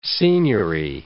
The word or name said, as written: signiory